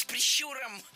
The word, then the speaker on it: прищур: Очень часто я слышу это слово с ударением на первом слоге, но это ошибка. Наша гостья произнесла слово «прищур» абсолютно верно. Прищур, прищура, прищуром. Всегда ударение в этом слове ставим на второй слог.